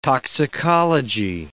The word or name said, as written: toxicology